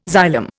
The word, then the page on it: xylem: Indian